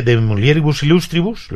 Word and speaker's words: De Mulieribus Illustribus: Escribe De Mulieribus Illustribus, lo he dicho bien, ¿no? Sí.